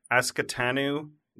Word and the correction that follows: Ahsoka: the other, like, she's a Sith or Jedi or something. I know her from one Ahsoka? – Of the... – Ahsoka? – Ahsoka, that's it.